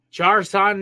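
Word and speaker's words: Jer'Zhan: So, number two, Johnny Newton out of Illinois, even though they pronounced it here on Pro Football Focus as Jarzon Newton.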